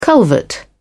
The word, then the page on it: culvert: British English